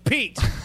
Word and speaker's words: Piet: Admiral Piet wondered if maybe he had made a mistake because his name is pronounced Pete.